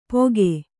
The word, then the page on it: ಪೊಗೆ: Kannada